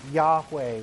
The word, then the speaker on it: Yahweh: That's referring to his name, Yahweh, which is the best way we know how to pronounce it to date.